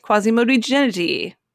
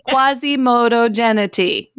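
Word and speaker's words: Quasimodogeniti: Laetare, Quasimodogenity. – Yes. – I totally mispronounced that. Let me try that again. Quasimodogenity.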